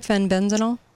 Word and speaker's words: fenbendazole: Am I saying that right? Fenbendazole? – Fenbendazole. – There you go.